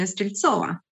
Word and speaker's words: Streltsova: My name is Dina Streltsova.